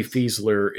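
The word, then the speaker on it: Fieseler: It's F I E S E L E R is what I have it that, That would be Fiesler if we're meant to do a German pronunciation of that, which I assumed we are.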